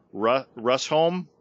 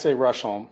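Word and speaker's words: Rusholme: So how do you pronounce this? Rusholm? Ruffians? – I say Rusholm, but I'm not from Manchester, so.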